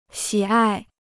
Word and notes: 喜爱: xǐ ài

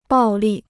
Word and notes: 暴利: bào lì

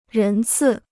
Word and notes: 人次: rén cì

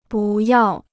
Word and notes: 不要: bù yào

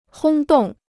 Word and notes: 轰动: hōng dòng

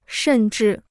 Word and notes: 甚至: shèn zhì

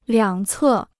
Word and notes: 两侧: liǎng cè

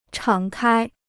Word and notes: 敞开: chǎng kāi

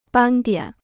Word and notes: 斑点: bān diǎn

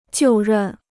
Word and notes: 就任: jiù rèn

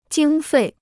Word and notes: 经费: jīng fèi

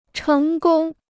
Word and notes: 成功: chéng gōng

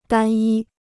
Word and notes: 单一: dān yī

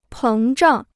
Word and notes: 膨胀: péng zhàng

